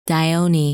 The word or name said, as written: Dione